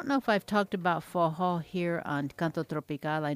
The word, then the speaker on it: forró: And then I always forget that I need to spell forró, because it's pronounced forró, but it's spelled F-O-R-R-O, forró.